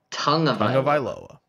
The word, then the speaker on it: Tagovailoa: Tagovailoa. Tongue. Like your tongue.